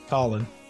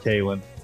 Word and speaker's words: Khalen: Khalen Saunders. Colin. It's not. – It's, it's Colin. not. – It's not Kalen.